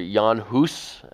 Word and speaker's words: Jan Hus: John Huss, or Jan Hus, as it's more correctly spelled and pronounced, but John Huss was a Bohemian reformer just a generation after Wycliffe in the late 1300s, early 1400s.